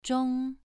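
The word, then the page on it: zhong: tone 2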